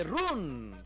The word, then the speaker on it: run: Sí, pero no se dice run. Se dice ¿cómo se dice? – No, run. Es incluso casi más con A.